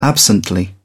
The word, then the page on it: absently: British English